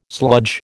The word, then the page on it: sludge: American English